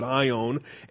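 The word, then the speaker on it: ion (αἰών): And we understand the terminology, and we're not going to do word studies on this morning, but ion, A-I-O-N, it's the long O, it's the omega, alpha, iota, omega, nu, ion, number 165.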